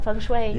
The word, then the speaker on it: feng shui: Yep, feng shui.